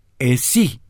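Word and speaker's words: esi (εσύ): Tú, esi, se escribe con la E de Erika, S de Santiago, I de informar, E de Erika, S de Santiago, I de informar. La I también lleva acento, es esi.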